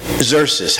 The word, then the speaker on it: Xerxes: X-E-R-X-E-S. Xerxes. You figure that out. That's how you pronounce it.